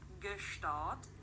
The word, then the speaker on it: Gstaad: Do you pronounce the G? – Discussion. I'll have to refer back to whichever episode it was that Sarah corrected our pronunciation. – How you say it? Gstaad. How we say it? Gstaad.